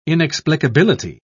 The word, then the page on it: inexplicability: British English